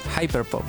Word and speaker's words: hyperpop: Y mezcla sonidos de Jersey Club, Hyperpop o Hiperpop, ¿cómo se dice? – Hyperpop. – Ya, Hyperpop. Europop, mira quién llegó.